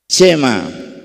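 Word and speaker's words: Chema (שמע): Yo quiero escuchar tu palabra a través de la palabra hebrea que, que se pronuncia Chema, que significa oír y hacer, que es igual.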